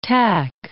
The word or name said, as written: tack